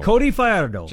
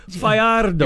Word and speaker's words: Fajardo: It's Cody Fajardo. – Cody Fajardo. – Just say it. – That's how you say it. You're supposed to roll the R.